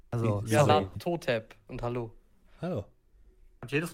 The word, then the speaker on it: Nyarlathotep: Totep. Die Betonung ist auf das To. – Nialatotep.